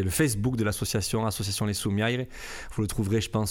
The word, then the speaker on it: Soumiaires: Alors, Les Soumières, L-E-S, plus loin, Soumières, S-O-U-M-I-A-I-R-E-S, Soumières, mais qu'on prononce Soumières en occitan.